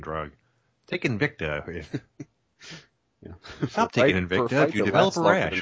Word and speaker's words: Invicta: But she's fighting for the 125 pound belt in Invincia. I think that's how you say it. – Invicta.